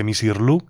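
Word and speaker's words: Misirlou: Así que Misirlu, que es como lo acabaron pronunciando en griego, se podría traducir como mujer egipcia, según sostienen a algunos.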